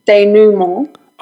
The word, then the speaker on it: denouement: Denouement. Am I saying that right? Denouement? – So you're the second person to say it like that. I think it's pronounced denouement.